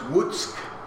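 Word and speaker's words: Łuck: Tuesday the 12th of September, journey on towards Wutsk, that's pronounced Wutsk, difficult language.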